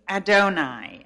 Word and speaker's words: Adonai: And the first word we're going to look at is the word Lord, spelt with a capital L and small O-R-D letters. Capital L, small O, small R, small D. Lord. This means Adonai, A-D-O-N-A-I,